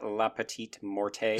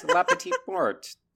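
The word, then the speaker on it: la petite mort: I will also quickly say, on behalf of everyone who speaks French, it is not La Petite Morte. It is La Petite Morte. The E is not hard.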